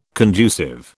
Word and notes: conducive: British English

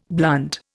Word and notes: blunt: Indian

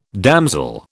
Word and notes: damsel: British English

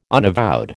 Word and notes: unavowed: American English

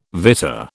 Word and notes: vitta: British English

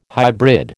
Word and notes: hybrid: American English

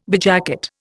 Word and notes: bedjacket: Indian

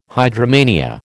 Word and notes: hydromania: American English

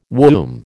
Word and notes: womb: American English

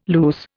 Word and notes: luce: Indian

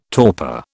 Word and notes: torpor: British English

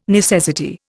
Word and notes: necessity: Indian

